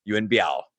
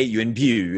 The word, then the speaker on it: Yuen Biao: And so my number five is Yuen Biao. – Nice. – AKA Yuen Biu, if you want to use the Cantonese pronunciation, as you probably should.